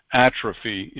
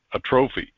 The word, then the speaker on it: atrophy: Normally, I get pronunciation fairly close, and I haven't used the word atrophy in so long, I mispronounced it as a trophy, which, of course, is not correct. So it's atrophy, like muscles and things like that.